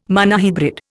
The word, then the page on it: monohybrid: Indian